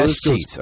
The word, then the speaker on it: escheats: So the S-sheets. I love that word, E-S-C-H-E-A-T-S. – Yeah. – Do I say it right, or is it S-sheets? – Yeah, that's right.